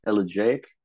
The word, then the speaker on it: elegiac: Is the word, is it pronounced elegiac? – Yeah. The quality of an elegy.